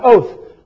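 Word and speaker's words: oath (אות): It's just O-W-T-H, and it's pronounced oath, like O-A-T-H, but it's O-T-H in the Hebrew.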